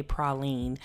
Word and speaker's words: praline: We had crawfish mac and cheese, and then we also went to a candy shop where we got to eat a praline. Yes, praline is the proper way to pronounce it if you don't want to be embarrassed by someone who's from New Orleans. It's not praline and it's not pecan.